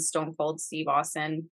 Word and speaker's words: Stone Cold Steve Austin: Definitely The Undertaker and Stone Cold Steve Austin, because, did I say that right? I'm sorry. – Yes.